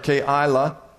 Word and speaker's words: Keilah: There's three syllables there. Keilah carried out repairs for his district.